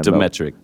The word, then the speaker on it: Dmytryk: I'm the host, Cameron Demetric, and now I have the co-host, Al Moore. – Al Moore's here. Can you say your last name one more time? – Demetric. – Demetric. – D-M-Y-T-R-Y-K.